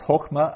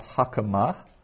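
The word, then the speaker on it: hokma (חָכְמָה): So that's hokma and not hakama.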